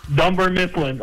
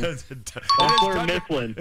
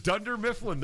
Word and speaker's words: Dunder Mifflin: Dunder Mifflin. Oh, I said it wrong. Dunder Mifflin. – It is Dunder Mifflin, the paper company.